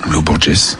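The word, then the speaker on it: Lô Borges: Comment vous l'appelez, vous? – Loborges.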